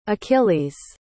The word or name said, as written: achilles